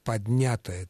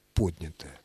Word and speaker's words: поднятая: Поэтому, конечно же, «поднятая целина» исключено категорически. Правильное ударение – поднятая.